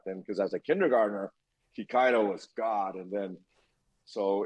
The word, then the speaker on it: Kikaida: And if anyone wants to Google it, it's spelled K-I-K-A-I-D-A, Kikaido.